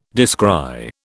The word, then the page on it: descry: British English